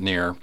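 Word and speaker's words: Kneer: And finally, Ruth Ann Rudy Neer, and that's spelled K-N-E-E-R, I'm going to pronounce it Neer, 84 of McGregor, Iowa, passed away on July 27, 2026, after a sudden brief illness.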